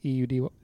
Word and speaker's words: Eudy: Yeah, I think it's saying Sid Udy, Eudy. Mm-hmm. That's how you pronounce the last name.